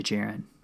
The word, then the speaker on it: Djarin: It's actually Djarin. D is silent.